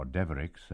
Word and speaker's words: Devereux: Jack Devereux, or Deverix, as this Norman name is pronounced locally, is a fisherman who has been singing the carols for over 50 years, thus maintaining a family tradition going back many generations.